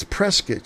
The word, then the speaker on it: Prescott: It is pronounced Prescott, not Prescott. They know you don't live here if you say Prescott. It's Prescott, just like a press kit, Prescott.